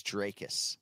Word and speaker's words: Dricus: So we emphasize the last syllable, and it is Dricus.